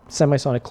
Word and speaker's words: Semisonic: Is that how you say it? – Yeah. – Semisonic.